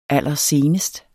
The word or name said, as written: allersenest